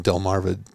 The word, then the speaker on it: Delmarva: The teams in the Delmar Valley, Delmarva area. I said that wrong. Delmarva, Delaware, Maryland, Virginia.